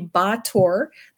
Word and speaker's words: Báthory: So we would pronounce it Báthory. It's actually Bator. They don't pronounce the Y.